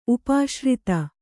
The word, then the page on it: ಉಪಾಶ್ರಿತ: kannada